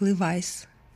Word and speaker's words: Levi's: Он пытался мне рассказать что-то связанное с фирмой Levi's, но по-английски Levi's произносится как Levi's.